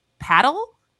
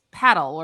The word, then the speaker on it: padel: And I think it's pronounced paddle. It's P-A-D-E-L.